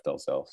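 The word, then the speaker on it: Dalzell: Instagram at Jeff Delzell Fitness. D as in dog, A-L, Z as in zebra, E-L-L is how you spell my last name. Or on Facebook, Jeff Delzell, spelled the same way.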